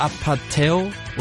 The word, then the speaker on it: APT.: Appetit übrigens nicht falsch ausgesprochen. Das schreibt sich wirklich APT. Ist aber koreanisch für Apateo oder so ähnlich.